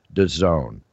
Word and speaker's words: DAZN: which for most people's edification is pronounced DAZN, the DAZN network.